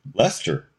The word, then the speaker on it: Leicester: But, yeah, I just was leaving London and I saw this street, Leicester Street, and I went, that's pronounced Leicester. Whoa. Okay. That's the name of the band. That's the Earls of Leicester. But then I had the I forget who it was. I don't actually forget who it was, but I won't embarrass them. They came in and to interview me from Rolling Stone and right away mispronounced the name of the band. And I had to start there. So this is the British pronunciation of this word.